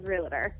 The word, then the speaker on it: realtor: Okay, so it is actually spelled correctly and pronounced realtor, but colloquially, everyone says realtor. – Right. – Realtor.